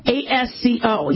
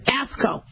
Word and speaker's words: ASCO: ASCO. Yeah, and that's how they pronounce it. ASCO.